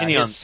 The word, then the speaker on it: Tynion: How do you say his last name? – Tynion IV. Yeah, Tynion.